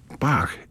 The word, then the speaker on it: B-A-C-H: Bach, but the musical tones B-A-C-H, or to pronounce them in German, B-A-C-H. B in German is not B, it's B-flat. So it's B-A-C-H.